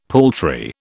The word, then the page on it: paltry: British English